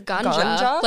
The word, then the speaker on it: Gunjah: In America, there was a company called, I might butcher this, but it's spelled G-U-N-J-A-H. – I think it's ganja. Like, that's where ganja comes from, probably.